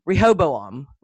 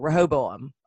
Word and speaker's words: Rehoboam: Here's how you say it. It's re, and then hobo, like a hobo, and then om. Rehoboam, Rehoboam, and then just put it all fast together. Rehoboam.